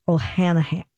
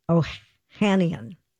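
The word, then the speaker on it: Ohanian: Alexis O'Hanahan. O'Hanian. Yeah uh, who is married to Serena Williams.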